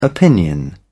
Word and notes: opinion: British English

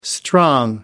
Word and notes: strong: American English

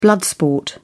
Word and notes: blood sport: British English

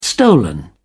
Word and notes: stolen: British English

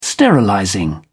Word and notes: sterilizing: British English

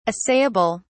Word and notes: assayable: British English

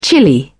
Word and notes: chilli: American English

